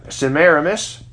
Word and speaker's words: Semiramis: But they worshiped the false trinity of Nimrod, Semiramis, and baby Tammuz. Now, Nimrod married, his wife was his mother, according to history. Semiramis, or some pronounce it Semiramis, either way, he married his mother.